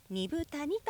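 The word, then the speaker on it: 二風谷: 里乃さん、原田くん、ラジオ講座を支えてくださっている関根健二さんが住んでいるアイヌの里、ビラトリ町二部谷にやってきました。自然豊かな場所にありまして、緑の森に囲まれ、猿川が流れる中、アイヌの伝統が色濃く残る地域です。二つの風の谷と書いて二部谷と読みます。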